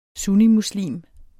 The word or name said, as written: sunnimuslim